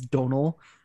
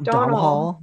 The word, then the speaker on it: Domhnall: So now it's like clicked in with me as Donal, not Domhnall.